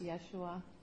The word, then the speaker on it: Yeshua: And so in the Hebrew name and the Aramaic name is pronounced Yeshua.